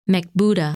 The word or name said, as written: Mekbuda